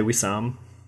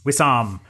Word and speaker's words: Wissam: And there's Janet looking amazing in all her glory, telling the world about her future plans with her husband, Wissam. I think that's how you say it, isn't it? Wissam? – I say Wissam. – Wissam.